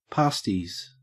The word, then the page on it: pasties: Australian English